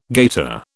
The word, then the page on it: gaiter: British English